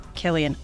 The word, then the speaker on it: Cillian: Killian.